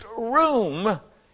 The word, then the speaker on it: ruwm: That little word extol is a Greek word, a Hebrew word R U W M, but it's pronounced room, and it means to rise.